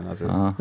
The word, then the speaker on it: Azure: och jag tror han sa Azure, va? – Azure. – Ja. Det var tre olika uttal på keynoten på PDC:n också. – Jag vill bara säga Azure.